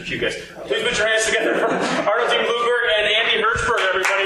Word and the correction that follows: Blumberg: Arnold, I know I mispronounced your last name, so could you please say it correctly? – Blumberg. – Blumberg. Why is that hard for me to pronounce? – I don't know why people can't figure that out when there's no E in it. The first part. – No, it's because of Bloomberg.